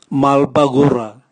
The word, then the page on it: malbagura: Raga